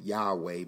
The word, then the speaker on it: Yahweh: Now, of course, in the Hebrew, it's YHWH, and that's how we pronounce it, Yahweh.